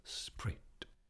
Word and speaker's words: spirit: Note here that for the line to scan, spirit is pronounced as one syllable, sprit.